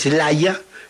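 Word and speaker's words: Slayer: Ese es Slaya. Sería la pronunciación en inglés británico. Para ellos la terminación er se pronuncia como a, Slaya.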